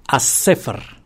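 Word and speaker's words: aṣ-ṣifr: Así que no es de extrañar que nos hayan quedado palabras como esta cifra, que lo pronunciamos as-sefer, as-sefer en árabe.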